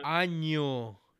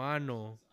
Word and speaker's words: año: año, no ano.